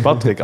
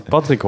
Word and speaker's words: Patrick: Als je mijn naam niet correct zegt, dan spreek je niet tegen mij. Snap je? Ik ben geen Patrick. Als ik Patrick hoor, dan denk ik aan een vent van 50, kale kop, bierbuik, die dat elke maandagmiddag in een café zit, te biljarten met zijn vrienden, snap je? Dat ben ik niet.